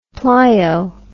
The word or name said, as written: plio